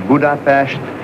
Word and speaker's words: Budapest: Ja, ungrarna själv ser Budapest, men jag håller mig till det svenska uttalet.